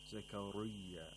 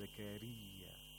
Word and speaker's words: زكريا: The راء of زكريا has a كسرة. Therefore, it should be pronounced with ترقيق, with thinness. Okay? So we pronounce the راء thinly. Okay, we don't pronounce it with thickness, with tafkhin. We don't say Zakariya, it's Zakariya.